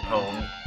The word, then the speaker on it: pwned: Online is frustrating because, as usual, I'm not the best player, so I'm constantly getting pwned. But, yes, I pronounce the P.